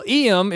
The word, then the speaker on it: Iam: Well, Iam is spelled I-A-M, and a lot of people are saying Iam home, I am home, and welcoming Iam back to a world where he doesn't have to worry about being priced out of anything.